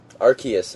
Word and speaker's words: Arceus: Arceus, I'm sorry. that's actually how it's pronounced, I'm not even kidding.